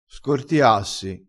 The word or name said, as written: scortiassi